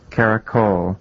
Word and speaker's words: Caracole: The definitive pronunciation is? – Caracole. It's spelled C-A-R-A-C-O-L-E,